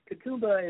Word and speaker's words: Katoomba: Is it pronounced Katoomba? – Yes, Katoomba is, they're almost like the Beatles of Nepal.